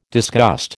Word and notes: disgust: American English

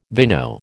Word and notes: vino: American English

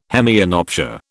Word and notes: hemianopsia: British English

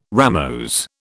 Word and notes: ramose: British English

